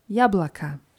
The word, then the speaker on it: яблоко: Mit O am Ende, aber ehe wir A ausgesprochen, das ist der Apfel, Jablaka.